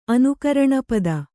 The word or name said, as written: ಅನುಕರಣಪದ